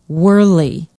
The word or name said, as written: werley